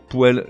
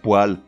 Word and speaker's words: poêle: Même remarque pour poêle, l'ustensile de cuisine ou l'appareil de chauffage. Non, on prononce poêle dans tous les cas.